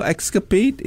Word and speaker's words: X-SCAP8: Yeah, so escapade is... – Sorry, beg your pardon. – Clever, because it's spelled X-S-C-A-P-8.